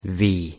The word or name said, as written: v